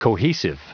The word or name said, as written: cohesive